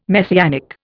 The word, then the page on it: Messianic: Indian